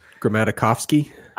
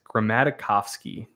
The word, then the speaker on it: Grammatikovsky: I should know how to pronounce his name. It's so many syllables. – Grammatikovsky. – I think that's correct. Grammatikovsky.